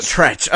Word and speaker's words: Treach: Oh, is it Treach? Okay. Yeah. I've got a lot of street cred, clearly. I think his name's... It's spelled Treach.